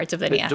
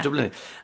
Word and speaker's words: Dublinia: Dublinia. You are, Dublinia. – Dublinia.